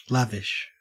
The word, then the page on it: lavish: Australian English